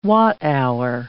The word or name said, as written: watt-hour